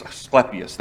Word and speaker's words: Asclepius: Asclepius, that's how you pronounce that.